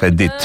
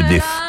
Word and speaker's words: Edith: Χαίρομαι που προφέρετε σωστά το όνομά της, Edith, και όχι Edith.